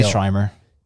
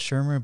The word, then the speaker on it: Schirmer: I say Schreimer. – Yeah. I, it's spelled it's spelled Schirmer, but I like to say I like saying Schreimer.